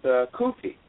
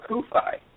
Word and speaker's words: CUFI: Yeah, I went to a college seminary, and I've gone back to community college recently, and I ran into some people with Kufi, or Kufi, depending how you pronounce it, C-U-F-I,